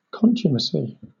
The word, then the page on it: contumacy: British English